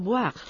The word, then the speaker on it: boire: Boire con una pronunciación oclusiva sonora, B, bilabial, B, porque si lo hacemos labiodental con la V, V, sería voir, lo que significaría ver.